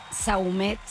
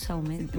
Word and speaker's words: Saumet: Saumet. – ¿Saumet? ¿Así pues, tal cual? – Sí, nosotros los criollos decimos Saumet. A los franceses como Saumet.